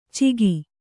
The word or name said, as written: ಚಿಗಿ